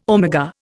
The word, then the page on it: omega: Indian